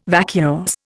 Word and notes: vacuous: Indian

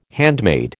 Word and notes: handmade: American English

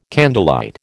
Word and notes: candlelight: American English

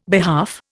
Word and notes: behalf: Indian

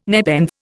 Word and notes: nepenthe: Indian

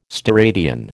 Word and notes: steradian: American English

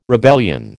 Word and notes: rebellion: American English

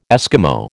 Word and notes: Esquimau: American English

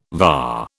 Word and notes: VA: British English

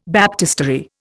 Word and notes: baptistery: Indian

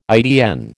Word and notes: ITN: American English

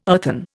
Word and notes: earthen: Indian

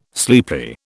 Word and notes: sleepy: British English

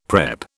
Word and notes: prep: British English

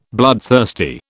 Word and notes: bloodthirsty: British English

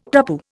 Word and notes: tapu: Indian

